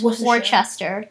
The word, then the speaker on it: Worcester: I think it's pronounced the Worcester Funeral Home. It's Worcester. W-R-C-E-S-T-E-R.